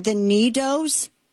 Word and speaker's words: NeeDoh: They were buying, and I guess I'm saying this right, the Needos. – Is that what it is? – N-E-E-D-O-H. Is it? I don't know if it's one word or not. – Yeah. – I will be corrected if I'm saying it wrong. I know. – Sounds right.